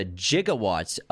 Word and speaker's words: gigawatt: But Doc Brown refers to gigawatts of electricity. This is the now obscure but once standard pronunciation of the word gigawatt, which is 1 billion watts. Nowadays, it is usually pronounced with a hard G, which is a G.